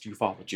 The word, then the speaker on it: ufology: It sounds funny, but it's pronounced ufology.